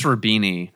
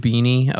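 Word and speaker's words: Roubini: I think it's Rubini, I believe. – Rubini, okay.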